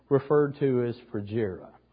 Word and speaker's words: Phrygia: And think of it as frugal, Phrygia. Phrygia is the pronunciation.